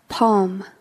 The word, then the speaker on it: palm: Palm, palm. – 손바닥으로. Palm의 원래 뜻은 palm이 벌림이란 뜻입니다. 손바닥을 벌림. 여러분, 손을 벌리면 펄림, 펼침이 되죠. 그걸 palm이라고 했습니다. Palm, L은 묵음이 되겠습니다.